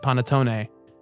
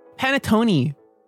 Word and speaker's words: panettone: And if you're a loser, you pronounce it panettone. – I know, what's her name? – Gia Coco De Laurentiis. – Coco Peru says panettone. – Panettone. Microwave. Yeah, get out of here. – Microwave. – Listen, if you're... No, it's pronounced panettone.